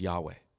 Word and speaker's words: Yahweh: But being German, they mispronounced the Yah. Instead of into a Jah, you know, Yah, Javol, Yah. And so so it got that Jehovah instead of a Yah, Yah. And so it should probably be pronounced Yahweh.